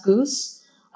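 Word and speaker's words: GOOS: Actually, it's pronounced Goose, and Windows and Go Build, and like you get a Windows binary.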